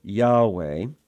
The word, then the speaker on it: Yahweh: It's probably pronounced Yahweh. It's made up of four letters. The vowel points seem to be ah and eh. And so Yahweh, it means literally he who is.